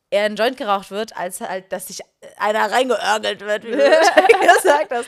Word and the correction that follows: reinorgeln: Ich habe es tatsächlich falsch gesagt, das heißt eigentlich reinorgeln.